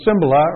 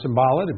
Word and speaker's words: Cymbala: And showed you a clip of Jim Cymbala, or Cymbala, depending on how you'd like to pronounce it, just admonishing a group of church leaders, Christians, that their church should be a house of prayer.